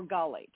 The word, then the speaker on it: gallate: Okay, the next one is prophylgalate. or gallate. It's pronounced both ways, G-A-L-L-A-T-E. It's found in pesticides, like what they spray on your lawn, like True Green.